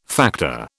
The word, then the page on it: factor: British English